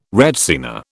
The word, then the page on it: retsina: British English